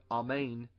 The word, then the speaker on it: amen: And they say, So be it, so be it, or truly, truly, which is what the Hebrew word amen means. When you see the word amen, or like we say amen, more correctly, it's pronounced Amen.